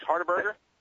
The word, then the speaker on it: Hardberger: Am I saying that right, Max Hardberger? – That's correct.